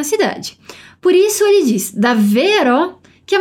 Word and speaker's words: davvero: Aqui essa palavra tem dois Vs, por isso aquela caprichada na pronúncia delle doppie consonanti, as duplas consoantes. Assim, davvero, davvero B, né?